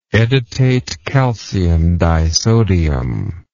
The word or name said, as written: edetate calcium disodium